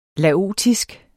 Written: laotisk

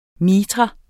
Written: MITRAEN

